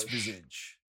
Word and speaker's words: Visage: I'm saying it's Visage. Because in English, a visage is when you see like an apparition or something.